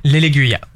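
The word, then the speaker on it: Laigueglia: Alors sur la prononciation de la course, je vais te la laisser. Ça se passe en Italie, ça sera dimanche, c'est le trophée... – L'Eleguia. – Voilà, l'Eleguia.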